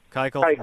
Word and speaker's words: Keuchel: Is it Keuchel? – Keuchel? Yeah, Keuchel.